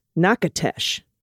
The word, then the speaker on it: Natchitoches: However, none of us could find a town, the town on the map, because in Louisiana, this town is pronounced Nacatesh, Hmm.